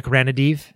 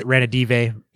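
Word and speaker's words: Ranadivé: Oh, Vivek Ranadive. – Right. – Ranadive.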